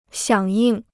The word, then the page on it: 响应: xiǎng yìng